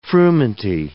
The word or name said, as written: frumenty